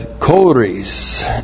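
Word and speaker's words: choris: And then the Apostle Paul adds Kores. C-H long O-R-I-S.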